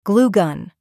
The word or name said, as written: glue gun